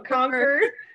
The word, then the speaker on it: Concord: Concord, a little bit more of an R. – The R sound, so Concord.